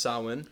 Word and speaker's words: Samhain: It is spelled S-A-M-H-A-I-N. So if you've seen that, it's pronounced Samhain.